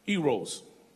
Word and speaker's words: eros: The first one that is also in the Bible, it's a type of love in the Bible, and it's pronounced eros.